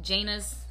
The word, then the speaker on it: Janus: Was it, is it Janus? Is that the January god, Janus? I think yes. that's how you say his name, yeah.